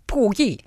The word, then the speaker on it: Puyi: Puyi. I think everyone understood that it's Puyi. – That was good. That was good, actually. I think that's actually how you say it.